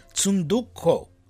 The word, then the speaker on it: Tsundoku: Tsundoku. Okay. It's T-S, and we Armenians know how to pronounce that T-S. It's T-S-U-N-D-O-K-U.